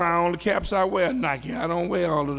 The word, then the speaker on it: Nike: Nike. N-I-K-E. But it's pronounced Nike. Nike.